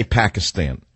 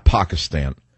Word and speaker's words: Pakistan: Americans say Pakistan, not Pakistan.